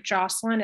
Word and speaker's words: Jocelyn: Um, my the best way to get in touch with me is my Instagram at wholistic Jocelyn.